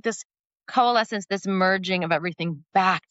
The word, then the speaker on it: coalescence: You develop what you call the consolence method. Now, am I saying that right? Consolence? – Coalescence. – Like it's marrying, I was close.